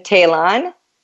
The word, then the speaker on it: Tallon: Facebook, it's Kirsten Talon, KEIRSTEN, Talon, TALLON, and the same with LinkedIn.